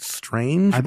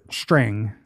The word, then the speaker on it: Strang: Is it pronounced Strange or is it Strang? – Strang. Yeah. Okay.